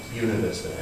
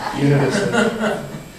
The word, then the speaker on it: Buena Vista: Buena Vista, as they locals pronounce it. Buena Vista.